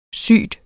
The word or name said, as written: syg